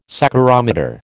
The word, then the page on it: saccharometer: American English